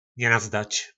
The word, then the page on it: nenazdac: po nasimu